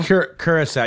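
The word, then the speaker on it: Curaçao: How do you pronounce that? Curacao? – Curacao. You got it.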